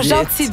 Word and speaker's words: Jeanty: Ah, oh, Gentil, donc on dit ça. – Ouais, s'écrit J-E-A-N-T-Y. – Okay.